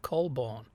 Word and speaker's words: Colborn: I said Colburn, didn't I, instead of Colborn. Looking at the way it's spelled, it must be pronounced Colborn.